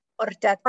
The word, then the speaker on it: horchata: Is that how you say it? Horchata? Horchata. – Horchata. Oh, horchata. – Yeah. – There you go.